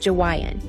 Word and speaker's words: Jawaiian: No, I didn't pronounce it wrong. I said Jawaiian.